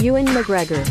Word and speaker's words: Ewan McGregor: Ewan McGregor.